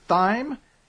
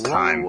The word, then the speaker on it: thyme: Says, Dear Y Ruler of Thyme. – It's Thyme. – I know. I just wanted to pronounce the TH.